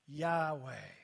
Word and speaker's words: Yahweh: Yod, He, Vav, He. Yahweh is how it's pronounced.